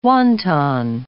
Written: wonton

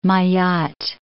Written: Mayotte

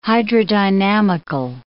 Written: hydrodynamical

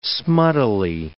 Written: smuttily

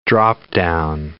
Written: drop-down

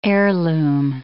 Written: heirloom

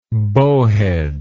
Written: bowhead

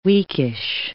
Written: weakish

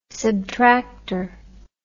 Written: subtracter

